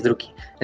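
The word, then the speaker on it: Drukhari: nunca supe cómo decirlo, ¿cómo se dice fácil Druki. – No, ah, pero sí es Druki.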